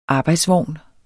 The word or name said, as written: arbejdsvogn